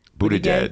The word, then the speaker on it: Buttigieg: Buttigieg? – Buttigieg.